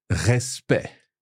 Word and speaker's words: respect: And at the end of the word, so E-C-T. So C-T, two consonants. So E, respect. – Respect, with a silent C in there.